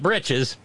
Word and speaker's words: breeches: B-R-I-D-G-E-S, with some lead bridges, not britches." Which is a dialectical, kind of comes, but well, it's a dialect pronunciation of breeches, B-R-E-E-C-H-E-S,